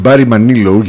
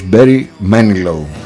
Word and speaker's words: Barry Manilow: Στην Ελλάδα το ξέρουμε ως Barry Manilow, γιατί τονίζουμε όπως μας βολεύει τα αγγλικά εδώ πέρα, τα αγγλικά ονόματα.